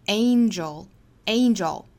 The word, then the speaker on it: angel: 实际上应该发 angel, angel, 这里面这个字母 A 同样是发双元音，所以跟后面的鼻音一组合发一个 N 的发音才对，就是 IN 的 N, N。所以这个单词里一定是会带出一个小小的 in, in, a, in, a, in, 从 a 到 e 再到 n, a, in, a, in, 再加后面的音就是 angel, angel, danger, angel.